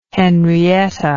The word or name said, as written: henrietta